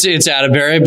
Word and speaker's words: Attebery: And did I butcher your last name right there? Like, was that right? – It's Attebery, but it's all good.